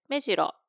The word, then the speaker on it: 目白: 目白、これはアクセント 1 で、この鳥は目白、アクセント 0 です。